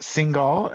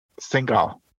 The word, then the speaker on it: Singhal: So, is it Singhal? Am I saying that correctly? – Yes, it's Singhal. – Singhal.